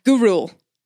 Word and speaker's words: Gurule: Gurule. I was just talking about this. It's G-U-R. Gurule.